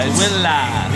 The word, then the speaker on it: Tooele: Just so you know, it's spelled T-O-O-E-L-E. Because we're all illiterate, so the W is implied. – Yeah. – Twilla.